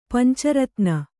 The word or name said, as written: ಪಂಚರತ್ನ